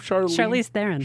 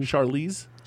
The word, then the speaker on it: Charlize: Charlize Theron. – Charlize.